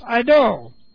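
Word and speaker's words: eido (εἴδω): This word for knowing is eido. E-I-D, long O.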